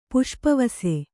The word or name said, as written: ಪುಷ್ಪವಸೆ